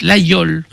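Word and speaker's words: Laguiole: Nous sommes allés aussi passer une semaine à Laguiol, pas la gueule, hein. On prononce l'aïeul pour les autres.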